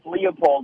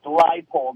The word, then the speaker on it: Leipold: So they said, you know, I've always called them Lance Leopold, but it's pronounced Leipold.